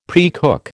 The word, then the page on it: precook: American English